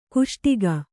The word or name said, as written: ಕಸೊಡಿಗೆ